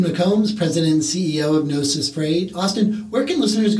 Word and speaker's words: Gnosis: And Gnosis has a silent G in front of it, G-N-O-S-I-S.